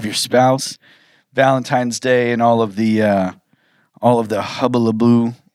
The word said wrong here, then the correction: hullabaloo: How do you say that word? – Hullabaloo.